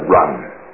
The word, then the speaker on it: Jen: God raised up a man by the name of Reverend Isaac Run, spelled J-E-N, but it's pronounced Run, a native of Shanghai, China.